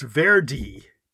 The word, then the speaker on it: Verde: And they're saying in the local Criollo dialect, it's pronounced Verde.